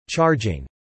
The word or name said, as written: charging